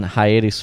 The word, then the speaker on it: hiatus: In fact, it just returned with episode 71 after being on hiatus for these last few months. I just pronounced hiatus like diabetes. Hiatus.